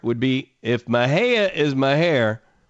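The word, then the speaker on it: Mexia: But it's also, and I'm not kidding you, if you lived anywhere around Waco like I did in the early 1980s, mid-1980s, a lot of people pronounce it Mahair.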